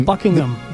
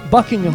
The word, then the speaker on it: Buckingham: Y de hecho, inglés perfecto, el H medio que se, es medio silencio. Buckingham. Buckingham. – Más o menos. – No se pronuncia mucho.